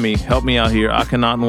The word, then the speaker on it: Akhenaten: Uh, Napoleon and I, I think it's Akenaton. God, I could have butchered that name. They have a they have a project together Akhenaten. called Akhenaten. There we go.